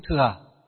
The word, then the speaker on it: thừa: Nhưng mà Phật giáo chúng ta đọc là gì? Chữ thừa. Phật giáo chúng ta phiên âm và đọc là chữ thừa. À, còn ở ngoài đời họ đọc là chữ thặng.